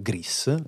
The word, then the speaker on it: Gris: Sono stato incerto sulla S, ma in realtà la pronuncia è proprio Gris, non sì, alla francese.